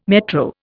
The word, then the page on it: metro: Indian